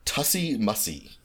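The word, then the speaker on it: tussie-mussie: It is uh, pronounced tussy mussy. Tussy mussy. Um, Tussy Mussy. T U S S I E dash M U S S I E.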